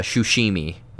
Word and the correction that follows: Tsushima: Ghost of it's Tsushima.